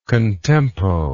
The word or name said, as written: contempo